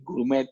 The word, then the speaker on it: gourmet: Entonces, el gourmet también es, es, es, es una palabra francesa que se dice así en, el mundo entero, no, no, no cambia, ¿no?